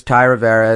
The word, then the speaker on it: Thai Rivera: My name is Ty Rivera. It's spelled T-H-A-I-R-I-V-E-R-A, which is also the same as my channel.